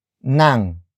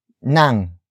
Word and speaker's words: ng: The next word is nang. Nang. Nang. Nang is spelled as ng.